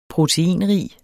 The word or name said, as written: proteinrig